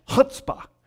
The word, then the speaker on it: chutzpah: and that's chutzpah. And you got it, yeah, you got to say it with that oomph on the first syllable, you know.